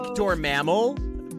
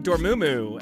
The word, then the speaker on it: Dormammu: Yeah, because I say it like Dormammal, but like you say it like Dormammu, and like you that's where it's at.